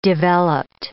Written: developed

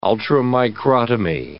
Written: ultramicrotomy

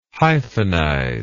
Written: hyphenize

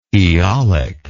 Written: eolic

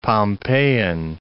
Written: Pompeiian

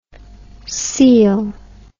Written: coele

